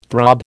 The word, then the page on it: throb: American English